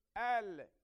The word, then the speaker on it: El: El is pronounced Al in Aramaic, Al in Arabic.